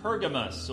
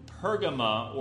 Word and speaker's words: Pergamon: Then, about 50, 55 miles north, message three was delivered to the city of Pergamos, or Pergama, or Pergamon. There's different ways to pronounce it.